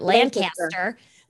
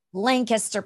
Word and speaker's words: Lancaster: I'm trying to say it correctly because I was told I was saying it wrong. I was calling it Lancaster. – Lancaster, Pennsylvania.